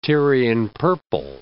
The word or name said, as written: Tyrian purple